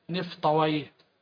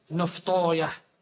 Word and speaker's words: نفطويه: ان اللغويين يقولون سيبويه نفطويه سلمويه، لكن المحدثين يقولون سلموية نفطوية هكذا.